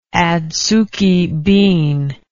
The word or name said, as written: adsuki bean